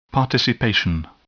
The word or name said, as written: participation